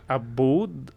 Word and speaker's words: Aboud: Abud, A-B-O-U-D. – A-B-O-U-D.